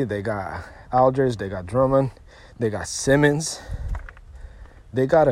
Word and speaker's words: Drummond: I don't know how to say the name, but it's Drummond, D-R-U-M-M-O-N-D.